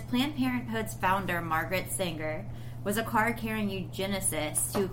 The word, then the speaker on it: Sanger: Margaret Sanger? Sanger? – Sanger. – Sanger? – Mm-hmm.